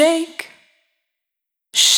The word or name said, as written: Shake